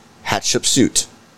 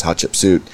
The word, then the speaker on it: Hatshepsut: They also call it, like, for Americans that are not able to pronounce Hatshepsut, they say hot chicken soup, and then you'll be like, oh, hot chicken soup.